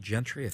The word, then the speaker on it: Gentry: Is that how you pronounce it? Gentry? – It is Gentry, yes.